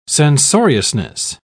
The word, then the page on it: censoriousness: British English